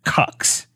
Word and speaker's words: Qux: And according to the Daily Beast, it is actually pronounced Cux. Q-U-X is pronounced Cux.